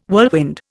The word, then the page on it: whirlwind: Indian